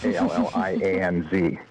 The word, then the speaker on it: Allianz: How it's pronounced is a big battle, but it's Allianz.